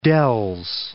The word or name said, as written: Wisconsin Dells